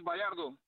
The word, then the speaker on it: Bayardo: It's Bayardo.